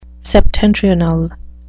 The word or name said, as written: septentrional